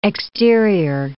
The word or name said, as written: exterior